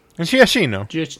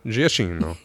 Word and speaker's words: Giacchino: Directed for the first time ever by Michael Giacchino. Is that how do you say his last name? – Giacchino. – Giacchino.